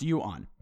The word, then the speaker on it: yuan: Yuan, pronounced yuan.